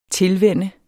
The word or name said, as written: tilvænne